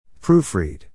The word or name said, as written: proofread